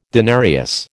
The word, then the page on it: denarius: American English